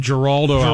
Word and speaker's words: Geraldo: When he was tossing Geraldo. – You know that G is soft. That's a soft G.